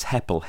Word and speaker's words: Heppell: It's Heppel, H-E-P-P-E-L-L. And I get called Mr. Apple and all sorts of things.